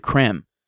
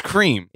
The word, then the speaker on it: Creme: Or is it creme? See, that's the question. – It's cream. – Is it cream, Griff? Cream or creme? – Well, considering I'm not a pretentious asshole, it's cream.